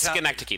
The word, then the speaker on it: Schenectady: all the way when he's up in Schenectady, right? Am I saying that right now? – Whatever. Well, it's Schenectady. The actual city yeah. – is Schenectady. – Yes, Schenectady. Yes.